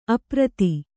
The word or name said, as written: ಅಪ್ರತಿ